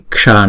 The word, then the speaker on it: ksha: Cái chữ ksha này nè là có chữ ka đó đó, ksha, ksha. Chữ s có dấu nặng này chúng ta phải đọc là uốn cong lưỡi lại và búng ra ksha, ksha.